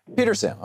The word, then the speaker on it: Petersham: Petersham, how do you say it in Massachusetts, the town? – Petersham.